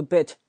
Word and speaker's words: bit: 而且那个 bit 那个 t 是不发音的，我们以后再讲为什么啊？OK，你要知道 chat 表示闲聊的意思。